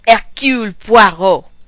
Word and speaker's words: Hercule Poirot: I think that for this segment, we need to say it properly. It's Hercule Poirot.